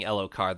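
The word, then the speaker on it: Elhokar: And yeah, he has said King Elokar, the H is silent.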